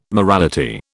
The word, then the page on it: morality: British English